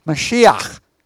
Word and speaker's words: Mashiach: Now, Messiah comes from a transliteration of the Hebrew Mashiach, anointed one.